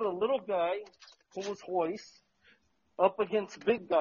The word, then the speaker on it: Royce: But they say Hoyce, but it's actually spelled with an R.